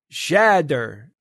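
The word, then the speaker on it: tjäder: This week's noisy is what we in Sweden call a chader or chader, pronounced like shatter with a drawn out A. So it's shatter with a drawn out J. So it's shader.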